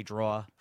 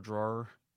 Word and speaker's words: drawer: I say draw for drawer. – Yes. – You know, I still do that. – I do that too. – Yeah. That's not the right way to say it.